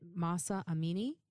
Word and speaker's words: Mahsa Amini: Is it Mahsa Amini? – Yes.